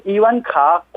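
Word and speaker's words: 이완카 (伊万卡): 중국에서는 이미 40개 회사가 발 빠르게 이방카의 중국어 발음은 이완카라는 이완카가 포함된 그 상표 등록을 마쳤습니다.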